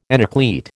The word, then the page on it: enucleate: American English